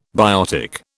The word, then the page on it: biotic: British English